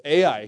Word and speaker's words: Ai: Literally, it's spelled Ai, and that's how it's pronounced too. Ai.